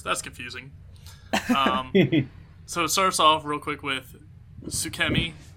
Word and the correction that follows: Tsukimi: right? Tsukimi. I'm terrible. – You were close.